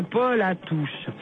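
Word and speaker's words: polatouche: Qu'est-ce que c'est qu'un Paul à touche? C'est une bête. – Paul, c'est comme le prénom? – Non, alors ça s'écrit P-O-L-A-T-O-U-C-H.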